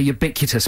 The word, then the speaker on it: ubiquitous: Make an observation that hasn't before been made about the ubiquitous Big Brother. And we've got some good ones. Is that the right way to say that word? – Ubiquitous.